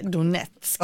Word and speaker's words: Donetsk: Och jag tror att det uttalas Donetsk. – Ja, det är möjligt. Men på svenska uttalas det Donetsk. Ja.